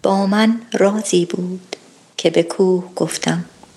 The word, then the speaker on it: رازی: Okay, and since this is such an important word in the poem, I want to emphasize رازی. The emphasis is on the first word, رازی. If you put the emphasis on the second word, it becomes an altogether different word.